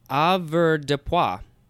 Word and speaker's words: avoirdupois: A-V-O-I-R-D-U-P-O-I-S. Avoirdupois? – Well done.